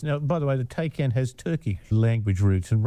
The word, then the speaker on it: Taycan: I might have said a Tay, but it's not that, is it? – No, it's a Taycan. – T-A-Y-S-N.